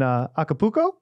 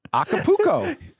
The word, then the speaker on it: Acapulco: Are you thinking about vacationing in Acapulco? – I'm glad you said it correctly. Acapulco.